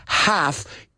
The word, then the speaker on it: half: Y fijaos, estoy pronunciando la L. Half. ¿He dicho half o he dicho half? Okay, good. Let's get rid of that L. I know it's confusing. They throw it in there, but we don't use it.